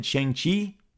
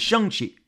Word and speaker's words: Shang-Chi: I have said Shang-Chi my whole life. I have now found that I am slightly mispronouncing it. It is Shang-Chi.